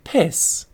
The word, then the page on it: piss: British English